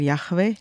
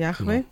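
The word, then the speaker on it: Jahwe: Und das wird unterschiedlich ausgesprochen, weil es ja im Hebräischen nur die Konsonanten gibt. Und ich sage zum Beispiel Yahweh dazu, genau. Yahweh, genau.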